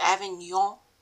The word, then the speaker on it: Avignon: I know it's pronounced Avignon, but, you know, I think in general when I say French words, they're just terrible.